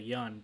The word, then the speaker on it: Yeun: and she kept emphasizing the Yun, because I feel like for however long he's been famous, everyone's been saying Steven Yun, but she's like, yeah, Steven Yun and I are, you know, we had a great time on the show.